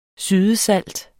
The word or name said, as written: sydesalt